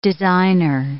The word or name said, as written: designer